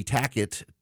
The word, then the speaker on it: tacit: Now, I got to say something because Mike just emailed me, and Mike is like the fifth person this week to say, actually, Tackit, T-A-C-I-T, should be pronounced Tacit. There's Tacit. You know what? I am in my late 40s, and I have said it Tackit the entire time because I'm an American, and most Americans say Tackit. They don't say Tacit, and I will fall back on the Winston Churchill quote that the French insisted that Winston Churchill should call Paris Paris.